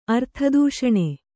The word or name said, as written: ಅರ್ಥದೂಷಣೆ